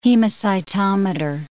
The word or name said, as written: hemocytometer